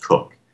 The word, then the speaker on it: Coke: That's spelled C-O-K-E, but it's pronounced Cook.